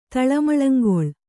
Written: ತಳಮಳಂಗೊಳ್

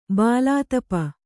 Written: ಭುಲತಾಪಿ

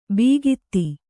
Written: ಬೀಗಿತಿ

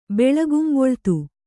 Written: ಬೆಳಗುಂಬೊಳ್ತು

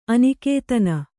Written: ಅನಿಕೇತನ

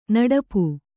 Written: ನಡಪು

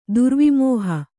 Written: ದುರ್ವಿಮೋಹ

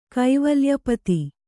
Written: ಕುವಲಯಪತಿ

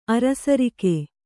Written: ಅರಸರಿಕೆ

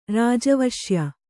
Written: ರಾಜವಶ್ಯ

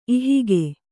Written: ಇಹಗೆ

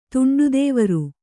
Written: ತುಂಡುದೇವರು

